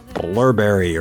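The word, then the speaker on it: Blubrry: Blurberry, or it probably is just pronounced Blueberry, but it looks like it's spelled differently.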